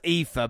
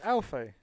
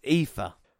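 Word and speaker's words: Aoife: It's pronounced Eva, but I know that cannot be a name. A-O-I-F-E. I'm sorry, but... – It's Alfie. – It's Eva.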